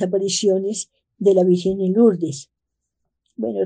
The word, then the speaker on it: Lourdes: Se pronuncia Lourdes, en Francia, cerca de España.